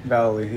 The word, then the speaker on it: Vallely: It was always, correct me, how do you say his last name? Mike Vallely. – Vallely, yeah.